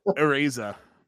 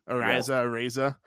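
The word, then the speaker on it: Araiza: They did sign a quality one, but San Diego State's Matt Areza, I think that's how you pronounce his name, Areza.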